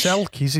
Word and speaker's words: selkies: Selkies in Scotland.